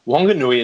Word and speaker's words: Whanganui: And on the west coast of the North Island, Whanganui is pronounced with the W as in whistle rather than the F sound. So closer to the expected way.